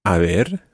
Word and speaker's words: haber: Now, it's quite easy to get this mixed up with another word in Spanish, a ver, which sounds exactly the same, but is spelled H-A-B-E-R.